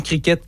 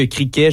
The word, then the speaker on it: cricket: Et donc, ouais, ça se prononce autant cricket que criquet. Je sais qu'on entend, je crois, les deux dans le reportage, selon le site de l'Université de Sherbrooke, le T, on peut le prononcer ou non.